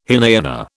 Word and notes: Hinayana: British English

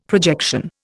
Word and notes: projection: Indian

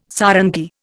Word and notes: sarangi: Indian

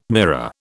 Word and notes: mirror: British English

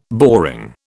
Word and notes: boring: British English

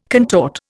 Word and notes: contort: Indian

